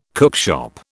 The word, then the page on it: cookshop: British English